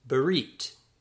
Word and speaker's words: berit: Word 53, covenant, is pronounced berit, berit, berit.